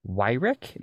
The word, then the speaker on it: Wyrick: And so we have Ezra Wyrick.